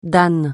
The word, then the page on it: dan: francais